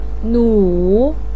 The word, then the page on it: nu3: pinyin